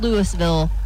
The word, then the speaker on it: Louisville: Tonight's song that time forgot comes to us from a band out of Louisville, Kentucky. Not Louisville, not Louisville. You gotta say it like you got a mouthful of marbles. It's Louisville, Kentucky.